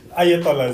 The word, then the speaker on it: Ayatollah: The Iran Supreme Leader, I don't know how to pronounce this, Ayatollah Ali Khamenei. – Ayatollah is good.